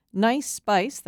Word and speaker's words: Gneiss: Nice Spice, that's spelled G-N-E-I-S-S, like the bedrock, common in parts of Maine.